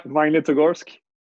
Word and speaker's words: Magnitogorsk: So how do you wind up in, is it Magnitogorsk? How do you pronounce that? – Yeah, that was good. The first one was good. Magnitogorsk.